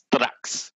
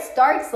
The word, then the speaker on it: Strax: Quick correction for you, and it's not your fault, but the title of the TikTok series is in Dutch, so it's Strax. – Strax? – Yeah, that's how you pronounce it, like a European. – Oh, I read Starks like Tony Stark.